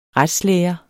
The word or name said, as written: retslære